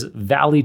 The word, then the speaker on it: VALL-E: It's another text-to-speech model, and this Valley term, it's spelt the same way as OpenAI's popular DALL-E series of text-to-image models. So it's VALL-E in all caps. It's supposed to look like WALL-E, the Pixar robot, in terms of spelling.